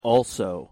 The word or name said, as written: also